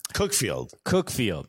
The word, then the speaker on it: Cuckfield: Don't they pronounce it Cockfield, which... Cookfield. Yeah.